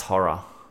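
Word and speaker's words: Ho99o9: Pronounced horror.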